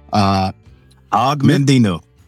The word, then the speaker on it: Og Mandino: Og Mandino.